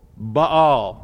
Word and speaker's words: Baal: Now see, some people wrongly pronounce this Baal. But you see, I personally know that it's not pronounced Baal in its singular form, nor Baals in its plural form. Okay, so I always make this emphasis. This is Baal and Baals.